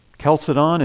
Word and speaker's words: Chalcedon: Chalcedon is spelled C-H-A-L-C-E-D-O-N. Sounds like a K, but it's C-H, Chalcedon.